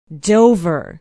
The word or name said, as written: dover